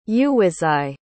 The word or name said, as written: uwizeye